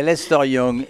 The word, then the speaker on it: Lester Young: Si je le prononce bien. – Tu l'as Lester. Très bien prononcé, Lester Young.